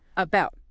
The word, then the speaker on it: about: Okay, next, about. – About. The stress, the stronger part of the word, is on the second syllable, about. About. It's pronounced like above or around.